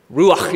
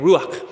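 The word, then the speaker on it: Ruach (רוח): Ruach, you got to get that in the throat thing. Ruach.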